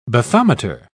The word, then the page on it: bathometer: British English